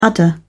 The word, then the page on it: udder: British English